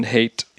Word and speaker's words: Haidt: Jonathan Haidt.